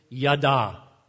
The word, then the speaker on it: yada: And the best attempt I can make at pronouncing it is yada, Y-A-D-A with an emphasis on the final A.